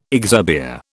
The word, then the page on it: exurbia: British English